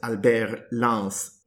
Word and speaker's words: Albert Lance: changed his name to Albert Lance which, of course, in French is pronounced Albert Lance.